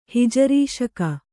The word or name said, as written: ಹಿಜರಿಶಕೆ